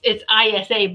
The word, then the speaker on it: Isa: I just want to say Isa, but it's ISA, but it's Isa. That's how it's pronounced.